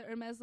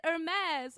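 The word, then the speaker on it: Hermès: and it was like the Hermes logo, and she goes, Hermes? And I was like, it's Hermes. And I felt like the most pretentious French bitch. It's Hermes.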